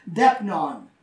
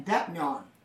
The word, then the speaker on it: deipnon: He says, I'll come in and I will eat a specific meal known as Depnon. It's spelled D-E-I-P-N-O-N, Depnon.